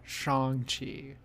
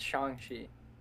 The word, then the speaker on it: Shang-Chi: I've been saying it wrong. It's me too. – Shang-Chi. – Yeah, it's Shang-Chi.